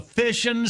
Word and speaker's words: Fission: You get through and take C, you'll get a Fishing Cycles prize pack. Sounds like fishing, but it's spelled F-I-S-S-I-O-N. – So it's the nuclear word.